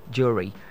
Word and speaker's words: jewelry: Jewelry, not jewelry. J-E-W-E-L-R-Y. Jewelry. Alright, so take note.